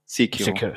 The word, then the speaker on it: Sekiro: Also wie gesagt, es wird Sekiro spricht man es, nur mal, dass ich das auch lerne. Sekiro.